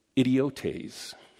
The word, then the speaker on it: idiotes: In the Greek, that word is idiotes. It's spelt I-D-I-O-T-E-S.